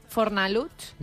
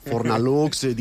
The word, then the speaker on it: Fornalutx: Te t'anava a dir, Fornalutx no deu ser fàcil de pronunciar ni pels castellano parlants de la península, no? Fornalutx. – Deu dir Fornalutx. – Aquesta X final... – Sí, és veritat. Fornalux, diuen...